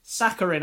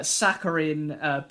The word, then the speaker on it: saccharine: How do you say that word? – Saccharine. – I got it right. Hooray. Yeah. Saccharine, basically a teen song, teen pop song, soft rock, horrible stuff.